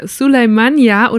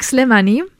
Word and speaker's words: Slemani: Suleimania oder Slemani? oder ganz anders, weil ich es ah, falsch ausgesprochen habe. Ich sag Slemani.